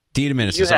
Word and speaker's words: DeDominicis: Is it a hard C or a soft C, Chad? Just straighten me out. – DeDominicis.